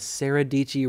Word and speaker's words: Sarah Dici: But she has really cool merch, and her tagline is Sarah Dici rhymes with peachy. If you want to go and check her out, it's S-A-R-A without the H, and then Dici spelled weird, but if you pronounce it phonetically, it'll come up, I'm sure.